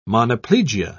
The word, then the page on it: monoplegia: British English